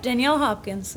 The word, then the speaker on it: Danielle Hopkins: Yep, Danielle Hopkins.